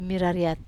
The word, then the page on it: marariat: wamesa